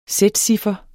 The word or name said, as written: sætciffer